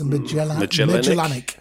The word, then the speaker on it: Magellanic: No, it's Magellan, isn't it? It's a Magellanic. – Yes, Magellanic.